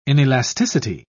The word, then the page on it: inelasticity: British English